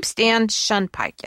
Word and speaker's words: Shunpike: Stan Shunpike.